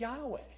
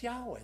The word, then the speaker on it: Yahweh: I said, that's Yahweh. That's Yahweh.